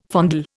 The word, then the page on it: fondle: Indian